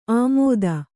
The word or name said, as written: ಆಮೋದ